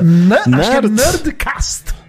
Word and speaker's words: Nerdcast: Como é assim que pronuncia? – Nerdcast.